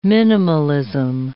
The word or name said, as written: minimalism